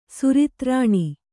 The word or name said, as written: ಸುರತ್ರಾಣ